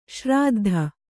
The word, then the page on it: ಶ್ರಾದ್ಧ: śrāddha